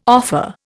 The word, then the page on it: offer: Indian